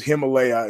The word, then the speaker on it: Himalaya: If you get the Himalaya app, and it's Himalaya, it's spelled like the mountain, you get to hear the new Aussie Confidential a day early.